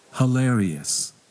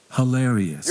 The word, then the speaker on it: hilarious: hilarious hilarious your turn hilarioush ilarious ถ้าเป็นอเมริกันจะออกเสียง hi เป็นเสียงชวาคือเสียงคำว่า huh นะเป็น everybody huh hilarious แต่ถ้าเป็นบริติชจะเป็น he นะ hilarious นะโอเคได้ทั้งสองนะ hilarious or hilarious ได้หมดเลยนะครับผมแปลว่า very funny นะครับผม comedy use humor what is humor everybody you have sense of humor If you have sense of humor, you always speak something that's funny and make your friend laugh.